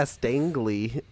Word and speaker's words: Stangely: Is that her name? Yeah, Stangly, and it's spelled S T A N G E L Y.